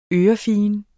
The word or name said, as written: ørefigen